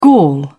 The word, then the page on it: gall: British English